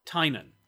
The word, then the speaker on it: Tynan: Yeah, it's not Tynan, it's Tynan. – I know. No. And by the way, official pronunciation of James Tynan the IV, Tynan.